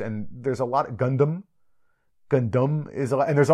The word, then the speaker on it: Gundam: How the pronunciation Gundam, is that the correct Japanese pronunciation? – Yeah, Japanese Gundam is how it's pronounced.